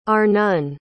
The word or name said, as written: Arnon